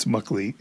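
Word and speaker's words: Meklit: I've mispronounced her name in the past. I apologize. Apparently it's Muckleet.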